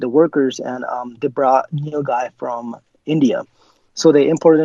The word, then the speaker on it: nilgai: N-I-L-G-A-I. – N-I-L-G-A-I. – It's that Neil guy.